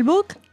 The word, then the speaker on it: Buch: Yo digo Buch, lo digo a lo alemán, mi abuelo era de Berlín, pero acepto que digan Buch.